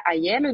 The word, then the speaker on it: Aiello: Sí, eh, bueno, en las redes sociales estoy en Facebook, en Twitter, en, Instagram, como soy Laura Ayelo y mi apellido se escribe A-I-E-L-L-O.